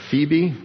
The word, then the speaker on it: Phoebe: I commend you to Phoebe, that's how it's pronounced, our sister, who is a servant, and that word is deacon.